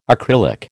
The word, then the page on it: acrylic: American English